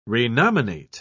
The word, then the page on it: renominate: British English